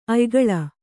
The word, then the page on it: ಅಯಿಗುಳ: ayiguḷa; Kannada